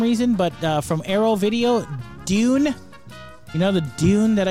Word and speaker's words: Dune: It's Dune. So Dune. – Yeah. Dune.